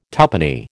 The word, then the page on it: tuppenny: American English